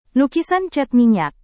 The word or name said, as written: lukisan cat minyak